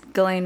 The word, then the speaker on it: Ghislaine: That's Ghislaine Maxwell's name. That's how you say it. – It's like an Australian kind of sound.